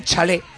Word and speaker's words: chalet: Pues Francisco Nicolás aka Lil Fran operaba desde un chalet, con acento en la E, de 4 plantas, para él solo, para él solito.